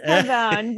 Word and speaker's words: Carbone: Because the only reason a lot of us know, is it Carbone or Carbone? – It is Carbone, but I think the traditional Italian pronunciation.